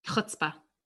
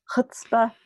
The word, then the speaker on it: chutzpah: However, Hebrew has that really specific like CH sound. – Yeah. Ugh. Chutzpah. – Chutzpah.